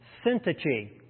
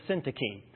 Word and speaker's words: Syntyche: But now he zooms into a very specific instance of disunity within the church, speaking to two women by name, Euodia, and I looked up the pronunciation, Syntyche, or you can resort to Syntyche, but I'm going to go with Syntyche.